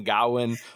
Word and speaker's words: Gawain: Todo el mundo pronuncia Gawain de diferentes maneras. – El único que le dice Gawain es el rey Arturo. Y todos los demás le dicen Gawen o Sir Gawain o